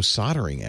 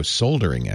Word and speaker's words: soldering: can never get used to that pronunciation. – They really pronounce it like that? – Soldering. Soldering, yeah. Oh. I know. Doesn't it sound... It sounds weird. – It sounds weird, but that's like how it's They think we sound weird. – Yeah. – But yeah, they're saying it right. – Mm-hmm. – We're leaving out the L.